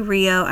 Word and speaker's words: Rio: And also, this is a great opportunity for me to acknowledge that when I say Rio, I'm pronouncing it in English with the R of R. And if I'm pronouncing it in Portuguese, the R sounds like an H.